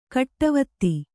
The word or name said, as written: ಗಾಡಿವತಿ